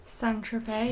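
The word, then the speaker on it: Saint-Tropez: Except this time, on the shores of Saint Tropez, how do you say that? Saint Tropez? – Saint Tropez, yeah.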